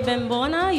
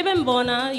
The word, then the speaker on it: Bembona: Well, everybody, mi gente, my name is DJ Bembona. You can find me Facebook, Instagram, Twitter, any interweb lands. You can find me through DJ at DJ Bembona. You spell that B-E-M-B-O-N-A.